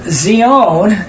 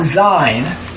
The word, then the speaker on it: Zion: And that Zion, it's not Zion, it's actually pronounced Zion, is the future habitation of Yahweh in Christ, which is not in any of our songs.